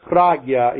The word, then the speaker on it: prajna: prajna paramita, pragya, if you want to put it in a more actual pronunciation in Sanskrit.